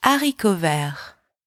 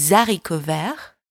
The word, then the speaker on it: haricots verts: The H in French is always, always silent, so you don't pronounce it. But this particular H here is called an aspirated H. It means that the liaison is not allowed. So you don't say des haricots verts. You say des haricots verts.